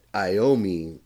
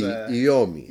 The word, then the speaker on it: Iommi: Actually, since you're taking Italian now, you probably know that Iommi is a mispronunciation because there is no I sound. In Italian. So it would be Iommi.